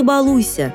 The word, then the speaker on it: балуйся: Вы ему «не балуйся с едой», на что ребенок может ответить: надо говорить «не балуйся», и будет прав. Ударение здесь следует ставить на второй слог.